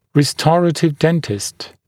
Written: restorative dentist

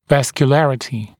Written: vascularity

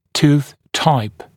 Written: tooth type

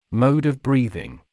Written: mode of breathing

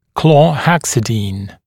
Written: chlorhexidine

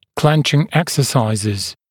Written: clenching exercises

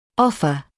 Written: offer

